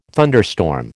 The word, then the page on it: thunderstorm: American English